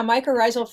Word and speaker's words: mycorrhizal: There's a phrase that comes up over and over again in the book, and that's mycorrhizal fungi. Am I even saying that correctly? – You sure are. Yeah, mycorrhizal fungi.